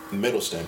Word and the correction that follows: Hiddleston: or Hiddleston, I believe.